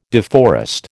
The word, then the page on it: deforest: American English